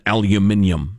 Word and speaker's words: aluminium: So, look, here's the way I see this this uh, the, the, steel dump the uh, the tariff thing with steel and aluminium, as I prefer to pronounce it.